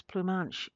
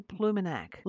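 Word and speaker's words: Ploumanac'h: So the lighthouse that we refer to as Plumanche is actually Plumenac Lighthouse in Brittany, France.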